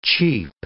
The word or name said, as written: cheep